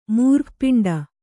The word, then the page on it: ಮೂರ್ಧಪಿಂಡ: mūrdha piṇḍa